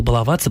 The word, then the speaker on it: баловать: А это тоже создает проблему, поэтому глаголу «баловаться» просто не повезло, потому что в разных частях речи с этим корнем ударение бывает и на первом, как в «баловень», и на втором, как «балованный», и на третьем, как «баловать» в слогах.